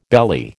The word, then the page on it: belly: American English